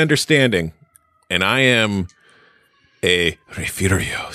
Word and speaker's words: Refugio: Like it's spelled Refugio, but it is pronounced Refugio.